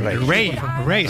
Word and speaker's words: Raye: Ray, se escribe Raye, Raye con Raye, y a Raye, Raye.